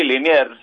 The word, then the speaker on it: Liniers: Liniers. ¿Se dice así? – Sí, Liniers, un Santiago argentino.